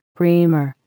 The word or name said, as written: reamer